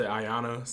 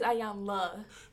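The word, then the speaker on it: Iyanla: I always say Ayana, so. – No, it's Ayanla.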